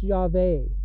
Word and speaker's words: Yahweh: And his name is actually pronounced Yahweh, and that's how you actually pronounce it.